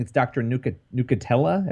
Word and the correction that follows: Nucatola: I believe that's how you pronounce it. Yeah, Nucatola.